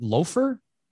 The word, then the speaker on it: Lothar: Speaking of spicy, the other release that I'm pretty excited about is from our friend Johnny from Lothar, but I think it's pronounced Loafer. I don't know if that's a British, English pronunciation.